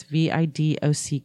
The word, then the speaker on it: Vidocq: Vodek Society, Vidoc, Vidoc. It's spelled, if you want to Google it, which it, it's fascinating. It's V I D O C Q. Right there. I would say Vidoc.